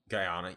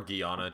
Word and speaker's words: Guyana: Guyana, yeah. Guyana or Guyana, just depending on who you ask. – We're going to go Guyana, if you ask me.